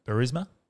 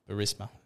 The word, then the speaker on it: Burisma: Burisma. – Burisma. – Burisma. Right. So Burisma and... – rhymes with charisma.